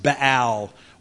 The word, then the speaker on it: Baal: And then you have something called Baal worship, or more accurately pronounced Baal worship.